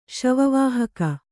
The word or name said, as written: ಶವವಾಹಕ